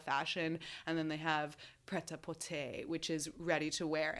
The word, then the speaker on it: prêt-à-porter: I looked up how to say this prêt-à-porter collection, which means ready to wear.